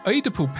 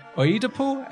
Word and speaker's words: Oedipal: Oedipal? Oedipal? – Yeah, edible. – Edible. – That's like edible.